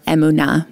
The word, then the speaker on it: emunah: Yes, the next word we're going to talk about, we mentioned already, it's the word emunah, or emunah, more properly pronounced in Hebrew.